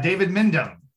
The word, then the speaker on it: Mindham: We have on at his secret undisclosed location, David Mindham.